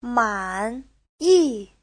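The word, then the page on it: 满意: man3yi4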